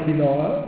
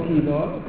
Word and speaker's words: quinoa: La quinoa, che qui in Italia chissà perché la chiamiamo quinoa, loro la chiamano quinoa, con l'accento sulla I.